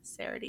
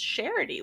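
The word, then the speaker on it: Seredy: for those that think I just mispronounced it, I did not. S-E-R-E-D-Y. A lot of you think it's Sarity. It's actually pronounced Charity, we discovered.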